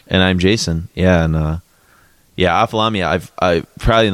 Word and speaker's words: Ophthalamia: This is off the Via Doloroso album from Ophthalamia, which is probably being pronounced wrong as well. – I think that's right. – Is it? – Yeah. – There's lots of H's in there, so I'm not sure.